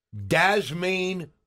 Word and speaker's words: Dazmain: His first name is spelled D-A-Z-M-A-I-N. Dasmaine Greenup.